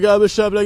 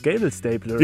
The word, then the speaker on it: Gabelstapler: Ach, so wird das ausgesprochen. Ich dachte, man nennt das Gable Stapler. Wie kommst du denn auf Gable Stapler? Bist du nicht ganz dicht oder was? Ich dachte, das ist Englisch.